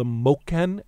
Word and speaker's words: Moken: Tell us the story of the time you spent with these people, the Moken. Am I saying that correctly? – That is correct.